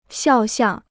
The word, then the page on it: 肖像: xiào xiàng